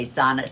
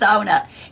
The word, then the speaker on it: sauna: But I have corrected people, you know, that say sauna. Say, oh, come on up here, it's sauna.